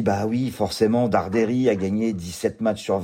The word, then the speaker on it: Darderi: Direction l'Open de Suède, Bastat, Nuno Borges, 52e mondial contre Luciano, si vous le prononcez à l'italienne, Darderi.